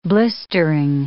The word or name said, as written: blistering